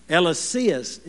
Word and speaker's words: Eliseus: Eliseus is the way you pronounce it.